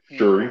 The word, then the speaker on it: Shuri: Siri. I always forget. I always mispronounce Black Shuri.